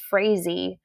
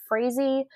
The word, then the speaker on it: Frazee: As you heard in the intro, I pronounced my last name as Frazee. I know a lot of people want to say phrase. It always cracks me up. The only time I use phrase is whenever I'm closing out my bar tab. It just keeps things simple. But everywhere else, I tell people that Frazee rhymes with crazy, and then they never forget it.